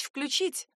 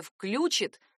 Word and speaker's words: включит: До недавнего времени ударение «включит» было таким же неграмотным, как и ударение «звонит». Большой орфоэпический словарь русского языка дал этому глаголу небольшое послабление. Отметил ударение «включит» как младшее допустимое. Это означает, что в литературной речи такой вариант по-прежнему недопустим, а вот в разговорной уже не так страшен.